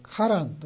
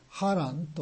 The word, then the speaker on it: ハラン: で、もともとはカナンをその父親のテラも目指していたんですけれども、途中のカランというところ、これはハランと読む方が言語に近いかもしれません。でカランとカナンではちょっと似ていますので、ここではハランと言いましょう。